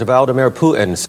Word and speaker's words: Vladimir Putin: President Vladimir Putin.